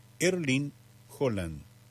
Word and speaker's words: Haaland: Con su habitual tono tranquilo, Haaland respondió que, fuera de Noruega, se le podía llamar Erling Haaland, tal como suele escucharse en las transmisiones internacionales. Sin embargo, cuando la periodista le pidió que explicara cómo se decía en su país, marcó la diferencia en la pronunciación. Según explicó el propio jugador, en noruego la pronunciación cambia por completo respecto de la forma en la que se popularizó en buena parte del mundo. De manera aproximada, su nombre se pronuncia como Erling Haaland.